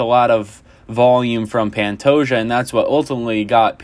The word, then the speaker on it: Pantoja: Pantoja, there it is. Alexander Pantoja. That is the correct way to say his name. I was saying it wrong last pod.